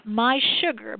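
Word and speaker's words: mySugr: Yeah, My Sugar, but it's spelled like this. It's just M and then S-U-G-R. So My Sugar, but it's M-S-U-G-R. It's an app. – M-S-U-G-R. – Mm-hmm. And the S is uppercase. – S-U-G, I wish they'd get easy now.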